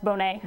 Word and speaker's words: Bonnet: So Charles, it's actually pronounced Bonnet. – Bonnet, oh. – We're very fancy. – French.